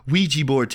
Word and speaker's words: Ouija board: So, have you ever messed with a Luigi board? – A Luigi board? – Yes. No. Ouija board, Tanner. You're saying it wrong.